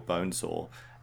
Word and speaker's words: Bonzo: One of the choices made in the film, in the casting of Bonzo, is or Bonesaw, is how it seems to be pronounced in the film,